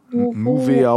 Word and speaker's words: Nouveau: Im Übrigen, Nouveau Beton schreibt man N-O-U. – V-E-A-U. – Ah. Also Nu mit OU. – Nuveau oh, okay.